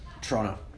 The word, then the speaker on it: Toronto: What's the correct pronunciation from a native Torontonian? Toronto. – Toronto with you a gotta, chop. You gotta, just let it roll. Don't pronounce all the letters. Just get it out.